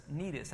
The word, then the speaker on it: Cnidus: That's Cnidus. That's how you pronounce that one. I can say that one, even though it looks different.